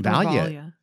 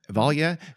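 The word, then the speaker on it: Valya: Said that it was Valia who told, and I hope I'm pronouncing her name right. It's V A L Y A. – Um, yeah, Valia, Valia.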